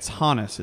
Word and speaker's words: Honus: But it's Honus is actually how you pronounce that name. Just a heads up.